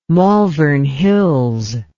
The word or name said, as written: malvern hills